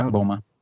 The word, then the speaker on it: BOMA: Like with the, how do you pronounce that? The BOMA project. – Yeah, BOMA. That's right. BOMA.